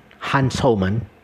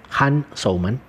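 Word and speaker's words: Jan Souman: Se escribe con J, Han, Han Soumann, y con S, como suena, Han Soumann.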